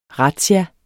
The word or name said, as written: razzia